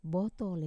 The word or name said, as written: botol